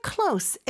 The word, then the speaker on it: close: 근데 여기서는 close s 발음을 꼭 해주셔야 돼요. 이게 S 발음이냐 Z 발음이냐에 따라서 품사가 완전히 달라지거든요.